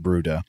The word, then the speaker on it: Bryde: So this would include the common minke whale, the Antarctic minke, the Brutus whale, that's spelled, it looks like brides, B-R-Y-D-E, but I think it's pronounced Bruda, the Sei whale, the fin whale, and the blue whale.